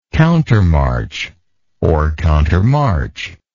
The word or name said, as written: countermarch